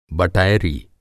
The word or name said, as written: बटैरी